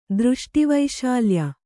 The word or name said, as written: ದೃಷ್ಟಿವೈಶಾಲ್ಯ